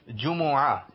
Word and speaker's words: جمعہ: جمعۃ المبارک اس کا صحیح تلفظ ہے جمعہ، جیم پر بھی پیش ہے میم پر بھی، تو کیا کہیں گے ہم؟